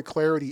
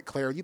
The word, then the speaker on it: Clardy: At Troy Clarity is the way to go. At Troy Clarity, the last name is spelled C-L-A-R-D-Y.